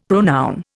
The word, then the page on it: pronoun: Indian